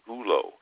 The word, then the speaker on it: Üllo: That's pronounced U-L-L-O for all of you out there. It's Üllo. Actually, with a little umlaut above the U, it just gives you a little different sound there.